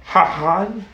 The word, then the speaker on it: Hahn: I think it kind of like Han, but H-A-H-N? – Shishi Hamni. – H-A-N? – Yeah. – Or H-A-H-N? – H-A-H-N. – Han? – Yeah, right? I guess the H is silent, so you say Han.